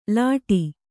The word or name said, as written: ಲೋಟ